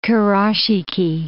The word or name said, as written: Kurashiki